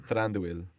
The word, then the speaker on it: Thranduil: Thranduil.